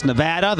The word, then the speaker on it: Nevada: Las Vegas, Nevada. That's a proper way to say it.